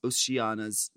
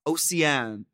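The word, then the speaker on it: Océane: As a proud French-Canadian, I'd love to help you pronounce Oceana's name, although the bitch is now long gone. So here we go. Instead of Oceana, her name is pronounced O-C-A-N. Oh. Mm-hmm. Why'd you spell it that way? Oceane. O-C-A-N.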